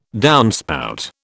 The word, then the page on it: downspout: British English